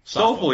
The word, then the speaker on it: Stoffel: Stoffel, yes.